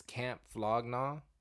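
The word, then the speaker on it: Camp Flog Gnaw: I don't know if I ever say this right, but it's Camp Flognaw, right? – Yeah.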